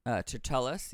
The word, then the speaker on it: Tertullus: Am I saying this right? – Tertullus, yeah. – Tertullus, okay, cool.